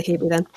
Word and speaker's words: Hebe: Anyone who's looking for the English people would call it Hebe. Yeah. – It could be Hebe. – No, trailing E's would definitely be pronounced. – Okay, I'll say Hebe then.